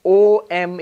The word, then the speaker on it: Om: But if you analyze that, split it apart, you get A-U-M. The closest we get in Sanskrit is, in English is A-U-M. But luckily, fortunately, when you write it as O-M in English, that's actually the correct pronunciation.